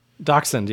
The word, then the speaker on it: Dachshund: How do you say that? Dachshund? – Dachshund, yeah.